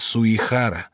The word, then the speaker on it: Суихара: Не Сугихара, а Суихара. Ты всегда так. Нельзя уродовать имена людей. Нужно быть внимательным. – Но пишется же Сугихара. – Пишется Сугихара, а произносится Суихара.